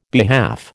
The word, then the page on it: behalf: American English